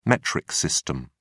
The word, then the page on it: metric system: American English